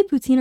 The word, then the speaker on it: poutine: Do you say poutine or poutine? – Poutine. – Oh. – Sometimes I say poutine. I do say poutine sometimes just because I'm used to saying poutine. The correct pronunciation is poutine.